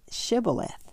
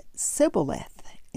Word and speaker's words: shibboleth: They had a hard time pronouncing the H in shibboleth and said sibboleth instead, uh, therefore giving themselves away.